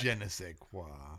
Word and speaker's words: je ne sais quoi: Or as I would say, Sauvignon does add a little je ne sais quoi, even though Rob's going to poo-poo-poo that. – Je ne sais quoi. – I'm impressed how you pronounce that.